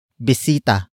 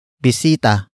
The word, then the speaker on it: bisita: The next word is ang, which is spelled as A-N-G. It is the focus marker that introduces the main topic of the sentence, and that is bisita. Bisita. Bisita. Bisita is spelled as B-I-S-I-T-A.